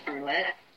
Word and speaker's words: Brouillette: My name is spelled Kimberly, K-I-M-B-E-R-L-Y, and my last name is Brouillette, B-R-O-U-I-L-L-E-T-T-E.